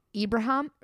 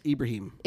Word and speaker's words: Ibrahim: Their star running back, and I'm going to butcher his name, Mohamed Ibrahim? – Close. Ibrahim. – Ibrahim. Okay. Mohamed Ibrahim, he tears his Achilles in that game.